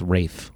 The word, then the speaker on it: Ralph: It's spelled Ralph. But it's pronounced Rafe.